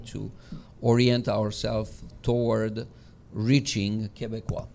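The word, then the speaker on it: Québécois: Quebecois is the right pronunciation of Quebecers. So this is how people from Quebec call themselves.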